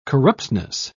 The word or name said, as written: corruptness